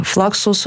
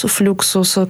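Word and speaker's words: Fluxus: На флаксус, флюксус, кому как больше нравится его произносить, как э, то место, из которого искусство пайка стартует.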